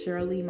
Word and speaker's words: Sherley: So when you download my app in the App Store, for Android, you just have to type Sherley Creatives as one word. Sherley, my name is spelled S-H-E-R-L-E-Y, and then you put Creatives with an S at the end.